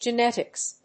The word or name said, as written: genetics